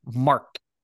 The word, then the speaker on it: Mark: Mark.